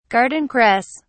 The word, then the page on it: garden cress: British English